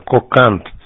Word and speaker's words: Коканд: Я немножко Китаеч твой, Кокон, это город твой, Китаеч, подскажи, пожалуйста, я что-то немножко не сориентировался на приеме. – Да, принято, нет. Это довольно известный город, город, ударение на А надо, Коканд